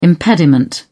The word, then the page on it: impediment: British English